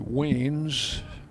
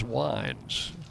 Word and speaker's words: Weins: Wines spells his name W-E-I-N-S. Suppose it could be Weens. Fly ball foul, that's going to get out of play, but it is Wines. Just check my pronunciation guide. Headed right the first time.